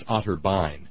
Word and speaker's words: Otterbein: It's Otterbein. Thanks, Isaac. That's how they pronounce it.